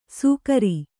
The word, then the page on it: ಸೂಕರಿ: sūkari; Kannada